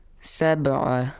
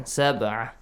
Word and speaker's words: سبعة: The letter ba has to be pronounced with qalqala sound. – Sab'a. – Ahsan. Sab'a.